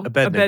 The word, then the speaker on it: Abednego: I've never heard it pronounced like a knee, but more ne. – That's what Abednego. – Abednego. – Abednego. – Yeah. – Abednego. Abednego. Abednego. – But I've always heard the emphasis on the bed part.